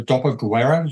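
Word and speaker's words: dopoguerra: I mean, so we're talking about the stories starting, as you said, immediately post-war in the Dopo Guerra, do they call it? Am I pronouncing it correctly? – Yes, perfect.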